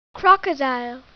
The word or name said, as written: crocodile